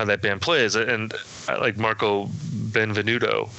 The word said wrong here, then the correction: Benevento: I think I said his name right, the keyboard player. – Benevento, thank you.